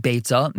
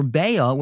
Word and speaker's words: Beitzah: And finally, unique to this Masechta is the debate on how to pronounce its name. Is it Beitzah, meaning egg, or Beya, which is the Aramaic word for egg? Now, both pronunciations are correct as long as you keep on steiging and don't let the pronunciation get in the way.